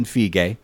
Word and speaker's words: Feige: Kevin Feige, that is how you pronounce it, by the way. – That is correct.